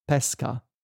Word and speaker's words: pesca: So this is not part of today's lesson, but for example, the word pesca, when I say it like this, pesca, it means... – Is that fish? – No, it's peach, the fruit.